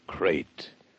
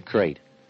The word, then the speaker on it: krait: First, the crate. You spell it K-R-A-I-T.